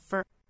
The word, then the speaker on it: for: I pant for the for the 两个词也要弱读，f o r 是弱读成 fur。